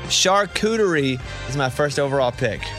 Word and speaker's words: charcuterie: Charcuterie? – Yeah, charcuterie.